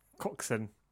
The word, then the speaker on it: Coxon: Coxon.